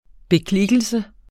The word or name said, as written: beklikkelse